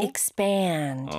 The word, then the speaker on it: expand: 그래서 두 번째 음절에 강세가 들어가니까 더 길게 늘어지게 발음해 주시면 좋아요. – 아, 이런 맞아요. – 느낌으로. – Expand.